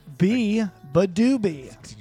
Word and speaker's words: Beabadoobee: B-E-A-B-A-D-O-O-B-E-E. Biba Doobie.